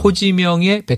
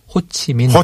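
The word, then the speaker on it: Hồ Chí Minh: 호지명의 베트남식 발음 호치민.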